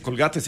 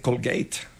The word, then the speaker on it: Colgate: Entonces, bueno, no se dice Colgate, se dice Colgate.